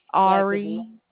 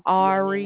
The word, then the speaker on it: Ari: Ari. – As to whom I know it's going to be a wrong name, but give it to Ari. – Me. Ari. Could you spell that, please? – A-R-I.